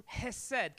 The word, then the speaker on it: hesed: The word mercy is the word in Hebrew, hesed. Can you say hesed this morning? If you're online, it's spelled H-E-S-E-D, H-E-S-E-D.